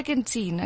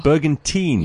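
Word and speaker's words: Bergenthuin: Elaine Bergenthwin. Is that right? Did I say your name right? – Bergentine, actually, yeah. – Bergentine. – Yes. – Okay. So it's T-H-U-I-N and it's teen.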